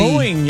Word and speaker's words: Boeing: Am I saying that right? Boeing? Is that Boeing, the... Boeing, yes, sir. Okay. You are correct.